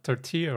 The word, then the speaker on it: tortilla: They also pronounced tortilla wrong. They were pronouncing the L's in it. – Like tortilla? – Yeah. Some of them were saying tortilla. And I was like, you guys, it's not tortilla.